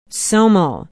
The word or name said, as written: somo